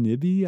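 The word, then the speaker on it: Nyby: Christian Nibby, I think is how you say his name.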